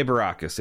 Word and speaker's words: Baracus: Baracus, yeah.